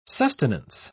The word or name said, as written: sustenance